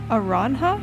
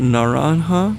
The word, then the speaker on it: naranja: Aranja? Is that how you say it? – Is it naranja? – Oh. – With an N at the beginning?